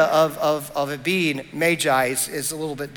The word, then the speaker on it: magi: Now, I don't know if you know or not that that word is actually, I've just found out very recently, that word is actually pronounced magi. Now, in my uh, world growing up and everything that I've said, I've always pronounced it magi, but the, the official pronunciation is magi.